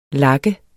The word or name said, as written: lak